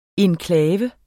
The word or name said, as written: enklave